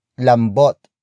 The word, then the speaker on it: lambot: Lambot. Lambot. Which is spelled as L-A-M-B-O-T.